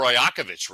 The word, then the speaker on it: Rajakovic: Yeah, let's go to Los Angeles, where Raptors coach Darko Arajakovic reached. – There it goes the mispronounced name section. – It's not Rajakovic. – I think it's Rajakovic, isn't it? – Rajakovic. Oh, sorry. Anyway, Darko Rajakovic reacted Tuesday night.